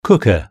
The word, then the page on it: cooker: British English